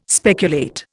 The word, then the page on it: speculate: Indian